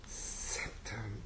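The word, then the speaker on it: sceptre: And the other one, scepter, the C is almost not there, isn't it? – No, it's not at all.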